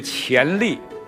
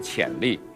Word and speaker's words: 潜力: 先提一点小小的字音方面的，亚鹏的这个供给啊，不能念成供给，是一的这个潜力，不是潜力，啊。